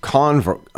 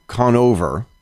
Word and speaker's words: Conover: Adam Conver- um, Conover, I think that's how he pronounces his name.